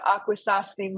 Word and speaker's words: Akwesasne: Looks like there's some very uh, fun chemicals that we'll be talking about here um, with the Akwesasne Mohawk women. – Well done. You got the pronunciation just right.